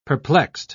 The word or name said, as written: perplexed